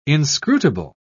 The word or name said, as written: inscrutable